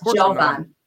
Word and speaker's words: Jovan: And now Yovan has agreed to come on my show. Yovan mm-hmm. Pulitzer. Am I saying his name right? – No, Adam. Okay, it's Jovan. Jovan.